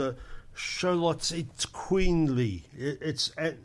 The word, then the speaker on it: Xoloitzcuintli: It's spelt X O L O I T Z C U I N T L I. If you look up, it says Sholo Eats Queenly.